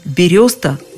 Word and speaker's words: береста: Многие настолько привыкли к бересте, что форму «береста» воспринимают в штыки. А зря. Вот, например, словарь ударений Агеенко и Зарвы этот вариант вообще приводят как единственный верный.